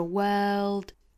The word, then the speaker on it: world: 哇哇，在这个哇的声音之中呢，再去找这个舌侧音，那发出来的音呢，应该就是 world world，再加上语调哈，world da world world world，啊，一定要注意是这样的发音。在发这个音的时候一定不要丢了舌侧音了，不然就会变成 WORD word 那个单词了，一定是 world 再加舌侧音 world world。